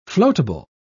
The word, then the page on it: floatable: British English